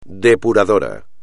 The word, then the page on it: depurador: espagnol